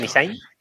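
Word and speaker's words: Nissany: A ver, Roy Nishain, Nishain, ¿lo he dicho bien? Correcto. – Nishain.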